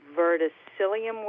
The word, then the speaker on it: verticillium: But I have some seeds that claim to be high resistant to verticillium wilt and all the other wilts that happen.